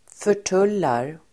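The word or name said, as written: förtullar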